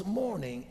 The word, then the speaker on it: morning: One signals morning, M O U R N I N G. The other signals morning, M O R N I N G. They are pronounced exactly alike, but the spelling is different.